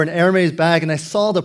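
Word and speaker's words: Hermès: I know that a Versace, or a, I recently found out the correct pronunciation, Hermes. I called it Hermes before.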